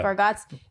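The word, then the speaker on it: Bargatze: And my guy, Nate now, how do you say his last name? Bargates? – Yeah. – Bargats.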